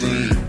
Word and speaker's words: zine: Don't be getting twisted. It's pronounced Zine.